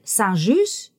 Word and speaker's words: Saint-Just: How do you pronounce Saint J-U-S-T in French? – Which one? Saint? – Saint-Just? – Saint-Just.